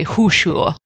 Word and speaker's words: Khuushuur: which is the huxue, it's spelled K-H-U-U-S-H-U-U-R. – Easy.